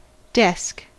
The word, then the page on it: desk: American English